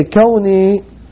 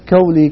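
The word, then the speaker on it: kawni (كوني): Okay, kawni is kaf waw noon ya, not kawli kawni, kaf waw noon ya, which means if you want to pronounce it in English, spell it in English, it will be K A W N, as in Nasir, E-E, Kauni. Kauni.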